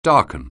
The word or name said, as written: darken